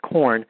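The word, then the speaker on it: QUORN: We've explored tofu quite a bit, but we've recently discovered this product called QURN, pronounced corn,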